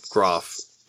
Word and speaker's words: Groff: the passing of Skip Groff. Is that, and that's how you pronounce the last name? I've always wondered. – Yes. – Okay. – As far as I know.